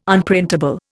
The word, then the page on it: Unprintable: Indian